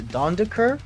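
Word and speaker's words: Dendoncker: I mean, they've got a former Wolves player, Dondeker? Is that how you say his name? – Yes. – It's not Doncic.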